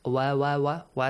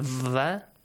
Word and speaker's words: و: Wow has to be pronounced like wa wa wa wa sound, right? Not va.